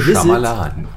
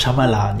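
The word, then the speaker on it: Shyamalan: Night Shyamalama, ich habe keine Ahnung, wie er ausgesprochen wird, Ding Dong. ähm, sein neuer Film, The Shamalan. – Visit. Shamalan, ja, genau.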